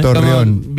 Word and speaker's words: Torreón: Torreón, bien, Torreón, bien.